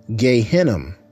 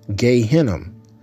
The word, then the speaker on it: Gehinnom: And Hebrew, it's Gehinnom, Gehinnom. We spell that in the English translation as G-E-Y,